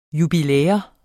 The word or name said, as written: jubilæum